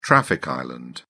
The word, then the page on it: traffic island: British English